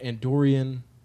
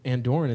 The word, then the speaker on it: Andorran: Do you call it Andorian? And we looked it up, and we believe that Andorran is the correct pronunciation.